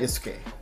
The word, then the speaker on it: Yasuke: Yasuke.